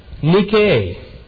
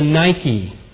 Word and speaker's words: nike: In fact, the noun form, nike, maybe you've heard we pronounce it the Nike, not Nike tennis shoes, but you've heard of the Nike missile.